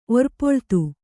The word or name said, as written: ಒರ್ಪೊಳ್ತು